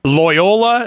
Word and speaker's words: Loyola: Loyola? Is that how you say it, Walter? – Correct. – I'm sorry? – Yes, correct.